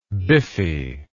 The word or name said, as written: biffy